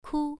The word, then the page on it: ku: 1